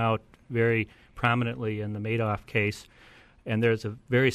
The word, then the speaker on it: Madoff: Well, indeed, the Madoff case, and that I'm understanding is pronounced Madoff as in Madoff with all the money, was a $50 billion and climbing loss estimate.